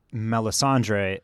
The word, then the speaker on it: Melisandre: Curious to see how Melisandre and...